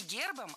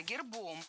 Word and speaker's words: гербом: Не гербом, а гербом. В этом слове при склонении ударение переносится на окончание. Поэтому гербом, гербами, гербах, гербы. Похожие на рассказчиков судьбы семейные фамильные гербы.